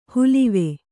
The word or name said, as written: ಹುಲಿವೆ